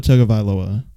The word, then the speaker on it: Tagovailoa: How do you say his last name? Tagovailoa? Yeah, Tua Tagovailoa. There we go.